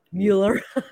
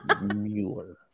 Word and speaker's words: Mueller: You have to say it like Euler. So I stop and I say it just like Mueller, Mueller, Mueller like just like the movie.